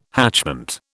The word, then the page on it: hatchment: British English